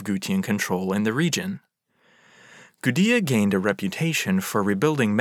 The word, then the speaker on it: Gudea: That light was shown by the Ensi of Lagash, a ruler named Gudea, spelled with a D, even though his name sounds quite similar to the people who had taken over Akkad.